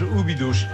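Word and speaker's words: Óbidos: Mais là, c'est un très joli village, Oubidouche. C'est une ville fortifiée. – Je précise qu'on ne se moque pas, mais c'est une prononciation qui nous fait plaisir. – J'essaye de faire la portugaise.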